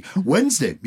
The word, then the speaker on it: Wednesday: Wednesday, you don't pronounce the D in Wednesday, and yet there is one.